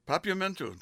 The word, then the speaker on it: Papiamentu: Papiamentu. – Papiamentu. Just like it sounds, just like it's spelled.